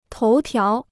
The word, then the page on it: 头条: tóu tiáo